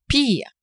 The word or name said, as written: pis